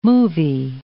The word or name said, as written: movie